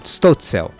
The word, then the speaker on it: Stölzel: A USP Filarmônica apresenta hoje obras do Beethoven, do Rubens Russomann Ricciardi e do Gottfried Heinrich Stölzel. Falei certo? – Stölzel.